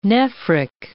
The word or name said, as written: nephric